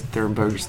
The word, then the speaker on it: Thunberg: You look at what, like, Greta Thunberg's doing and, did I say that right?